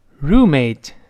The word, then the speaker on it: roommate: 室友读成，跟我读一遍，roommate roommate 这个字母 r 勾舌，后面发长音 u，roo roommate 这么一个声音啊。